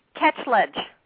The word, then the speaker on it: Ketchledge: Ketchledge.